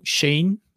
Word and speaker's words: Schoen: Dus misschien dat het ehm, onder het nieuwe management van, en ik vind het belachelijk hoe dat de Amerikaan moet uitspreken, maar Joe Shane, en er staat gewoon schoen, dat is eigenlijk de, de, nieuwe general manager van de Giants, Joe Shane.